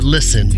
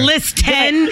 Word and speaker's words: listen: What letter is not pronounced in the word listen? – Shoot, I don't know. – It's a T. – There's T. – A T in there. – It's not list 10.